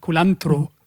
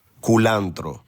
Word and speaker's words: Culantro: Hier also jetzt nochmal ganz kurz mit der übrigens viel schöneren Aussprache als meiner von Culantro. – Culantro. – Culantro.